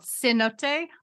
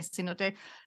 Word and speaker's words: Cenote: So it's considered a student Emmy, and it's called Cenote. I think it looks like C-note, but mm-hmm. it's pronounced a little differently. Cenote. C-E-N-O-T-E.